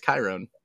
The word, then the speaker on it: Chiron: I think it's Chiron. Yeah. H is silent. Just like in hysterectomy.